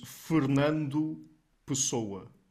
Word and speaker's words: Fernando Pessoa: Actually, the pronunciation in Portuguese is Fernando Pessoa.